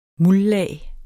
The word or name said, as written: muldlag